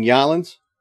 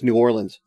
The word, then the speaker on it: New Orleans: You mean Yalins? – Yeah, that. – Ah, okay. – Mm-hmm. – Well, I mean, that's how I've heard it pronounced by so many people, Yalins. – I mean, I'm sure I don't doubt that, but it's. – But I'm just, you know, I'm trying it. So it's New Orleans.